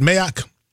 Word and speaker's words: Mayock: What I can appreciate about what Mike Mayock said, am I saying that right? Mayock? – Mayock, yep.